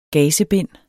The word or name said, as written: GAZEBINDENES